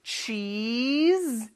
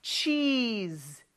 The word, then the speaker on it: cheese: 아니고 cheese. 이렇게 좀 길게 이를 끌어줘야 됩니다. 치즈 발음 연습해 볼까요? cheese, cheese.